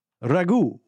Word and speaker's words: Ragu: Ragu. Ragu is how they say it.